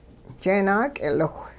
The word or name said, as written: jeenaak'iliwh